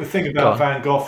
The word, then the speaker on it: Van Gogh: And the guy went, some people say Van Gogh, some people say Van Gogh, but we say Van Gogh.